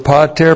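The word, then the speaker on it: Pater (πατήρ): The conjunction kai plus the accusative singular of pater plus the genitive singular of peritome. Kai is a continuative kai. It's correctly translated and. And then we have pater. P-A-T long E-R. Pater.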